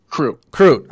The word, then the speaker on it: Crute: Jimmy Chute is how you say his last name? – Crute.